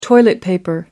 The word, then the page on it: toilet paper: American English